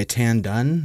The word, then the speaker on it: Tan Dun: Uh, the music for the film was composed by Tan Dun.